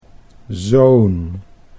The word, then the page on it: zo'n: Dutch